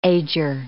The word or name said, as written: ager